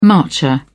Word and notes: marcher: British English

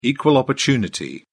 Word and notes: equal opportunity: British English

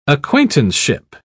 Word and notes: acquaintanceship: British English

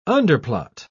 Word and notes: underplot: British English

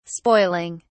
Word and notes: spoiling: British English

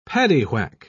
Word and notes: paddywhack: British English

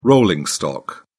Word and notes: rolling stock: British English

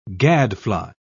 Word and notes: gadfly: British English

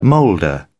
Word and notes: moulder: British English

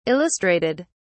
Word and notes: illustrated: British English

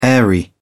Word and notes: airy: British English

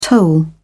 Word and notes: toll: British English

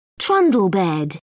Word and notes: trundle bed: British English

